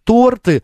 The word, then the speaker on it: торты: Так, Андрей Васильевич пишет: Макс, прошу в эфире сказать слушателям, что слово торты, ударение на О ставится, минутка ликбез.